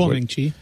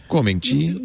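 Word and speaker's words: Guoming Chi (郭明錤): Or as you say, Guo Min-Chi, or whatever you say. I don't know how you say it. – Guoming Chi. – Guoming Chi.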